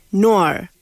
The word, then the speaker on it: Noir: Se escribe Spider, es araña en inglés, y Noir, que se pronuncia realmente Noir.